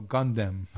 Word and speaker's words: Gundam: I'm like, I wrote down something about Mobile Site Gundam, but it's. – Mobile Site Condom? What? – No, Gundam.